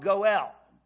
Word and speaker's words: Goel: In Hebrew, it's called Goel. G-O-E-L is how we'd spell that in English.